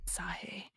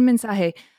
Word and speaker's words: mensaje: mensaje. Is that how you say it? Un mensaje. Ah, mensaje. Un mensaje.